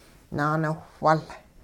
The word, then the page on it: naaniwhwul: hupa